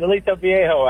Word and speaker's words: Aliso Viejo: He's out in, see if I say this right, Aliso Vallejo, California. Did I say that right? – Yes, you did. Aliso Viejo, actually.